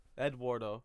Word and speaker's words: Eduardo: But you don't say it like it's Ed though. Eduardo. It's not, there's no W when you say I like the way you said it, too.